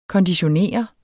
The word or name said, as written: konditionere